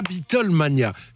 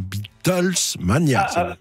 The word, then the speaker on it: Beatlemania: Alors, la Beatlemania, je précise, parce que je lis des choses, j'entends des choses, on dit bien Beatlemania et pas Beatlesmania. – D'ailleurs, les Belges prononcent bien, ils disent Beatlemania, alors que les Français disent Beatlesmania, qui est toujours le mauvais choix.